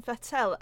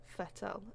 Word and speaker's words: Vettel: Isn't that not technically how it's pronounced? Vettel. It's like more of an F than a V, isn't it? – But it's not the F that's weird.